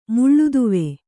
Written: ಮುಳ್ಳುದುವೆ

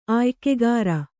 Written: ಆಯ್ಕೆಗಾರ

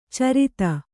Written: ಛುರಿತ

